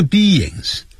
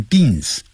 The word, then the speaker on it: beings: Human 就是人，人的 H-U-M-A-N Human beings. Beings 先念 B 再来一个 ings. Beings 啊，不要念 beans，因为 B-E 念成 B I-N-G 念成 N Being 啊 Beings。那么 human 可以把它省掉，我们是群居的生物， we are social beings.